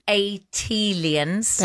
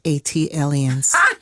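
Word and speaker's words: ATLiens: for Philly as an unofficial ambassador for Atlanta and Atelians. – That is pronounced A-T-Aliens. – Thank you. – Like Atlanta Alien. A-T-Alien. – A-T-Alien. Gotcha.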